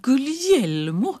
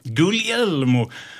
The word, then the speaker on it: Guglielmo: Guglielmo? – Guglielmo.